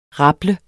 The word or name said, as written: rable